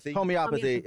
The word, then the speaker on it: homeopathy: Homeopathy.